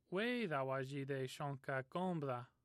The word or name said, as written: shonka